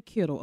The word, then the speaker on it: Kittle: I'm like, you say Kittle.